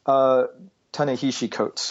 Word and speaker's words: Ta-Nehisi Coates: Well, that's I wanted to ask because, again, and I'm going to get his I'm going to mispronounce his name. Ta-Nehisi Coates? – Ta-Nehisi Coates, I believe.